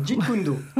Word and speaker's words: Jeet Kune Do: Non, nous vous invitons à faire connaissance du Kali, Eskrima FCS, du Jeet Kune Do. Ça se prononce comme ça? – Quasiment, Jeet Kune Do.